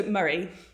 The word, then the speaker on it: Moray: It's almost like Murray, the surname is in M-U-R-R-A-Y. – Murray. Okay. I just think of a Flight of the Conchords when you said Murray like that. – Murray, but is it O? – But it is spelled M-O-R-A-Y.